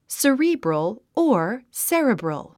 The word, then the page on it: cerebral: səríːbrəl; スリィブロォ